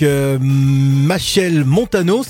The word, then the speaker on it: Machel Montano: On va s'écouter avec Machel Montano, c'est bien ça? – Oui, c'est ça.